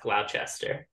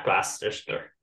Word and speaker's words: Gloucester: That's definitely pronounced Gloucester, not Gloucester.